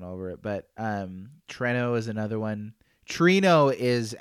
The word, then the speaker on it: Treno: How do you say it? – Treno. – Treno. That's how I said it too. Treno. – Which is funny because there's no I.